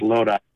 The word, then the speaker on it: Lodi: He's from Loudy, I think it's pronounced, California, from Central Cal. – It's Lodi. – How do you pronounce it? – Lodi. – Lodi? See, I don't Lodi.